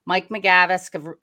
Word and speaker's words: McGavisk: Today, I am getting joined by Mike McGavisk. Am I saying that right, Mike, when I say your last name? – Okay. Exactly. That's correct. – Okay. I am joined today by Mike McGavisk,